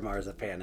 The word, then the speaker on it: Mangiapane: But Mary Berry with the assist on the pronunciation there. He is Andrew Mangiapane in our hearts and minds.